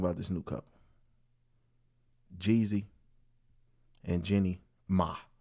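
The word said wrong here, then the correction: Jeannie Mai: That's how you say her name? – Jenny May.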